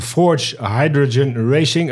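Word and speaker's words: Forze Hydrogen Racing: Abel van Beest van Forge Hydrogen Racing. Een mooie naam. Een studententeam uit Delft. Zeg ik dat goed, eh, Abel? – Ja, helemaal correct.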